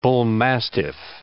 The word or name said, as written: bullmastiff